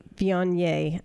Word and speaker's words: Viognier: And it really paired up well with the Viognier. I know I'm not saying that right, but anyways. – No, that was perfect.